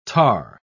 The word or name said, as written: tahr